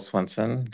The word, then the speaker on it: Swenson: My name is Michael Swenson. Last name is spelled S-W-E-N-S-O-N.